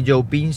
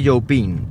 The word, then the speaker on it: Youpin: Xiaomi Youpin se escribe Youpin, como suena. claro, la duda es que yo había leído un montón de veces, este producto es by Xiaomi Youping, este otro producto es by Xiaomi Youping, y la verdad es que yo me quedaba un poco así frío.